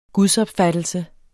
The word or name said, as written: gudsopfattelse